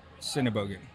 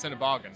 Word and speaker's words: Sennebogen: I pronounce it Sennebogen. – That's correct. Most of the Californians pronounce it incorrectly. They pronounce Sennebogen.